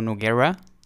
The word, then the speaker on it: Nogueira: Bruno Nogueira. Like you say your name. – Like Guerra, yes. Nogueira.